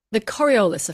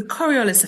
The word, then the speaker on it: Coriolis: Coriolis effect. – The Coriolis effect. Why is Yes. – it so important? – So the Coriolis effect is the consequence of being on a spinning planet.